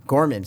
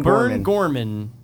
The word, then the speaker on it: Gorman: Burn Gormaj. – I'm sending it to you. Gorman, say it right. – Burn Gorman.